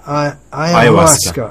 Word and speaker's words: Ayahuasca: Ayahuasca.